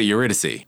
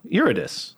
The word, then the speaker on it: Eurydice: How do you say it? – I always said it Eurydice. – No, Eurydice. Mm-hmm.